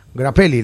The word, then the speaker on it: Grappelli: Y el violín este de Stefan Grappelli, ¿lo dije bien? – Sí.